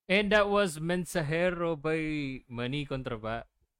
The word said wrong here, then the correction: Contador: Sorry, Baka mamaya mali pangalan eh. – Uh, Mani Contador.